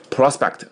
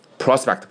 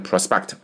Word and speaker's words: prospect: prospect, 注意发音啊，重音在第一个音节上。 prospect 不要念成 prospect, 是 prospect。好，你说，举个例子啊，说这个工作没前途啊，啊，这个晋升的机会也很小，然后呢，这个对自我的这个突破也没什么前途，对吧？